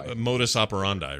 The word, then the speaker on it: modus operandi: Modus operandi, right? Is that how you say it? – Yeah, it is.